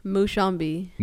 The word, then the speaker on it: Mushambi: Mushambi.